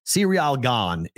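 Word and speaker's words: Ciryl Gane: Cyriel Gon is essentially how I think you're supposed to say it.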